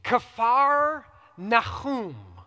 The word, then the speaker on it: Kfar Nahum: What does that say on there? Kfar Nahum. That's how it's pronounced. Capernaum is Greek. We're Greek. So Capernaum is Greek, but it's Kfar, and actually today, if you would see it, it's K-F-A-R, K-apostrophe-F-A-R, Nahum, N-A-H-U-M, village of Nahum.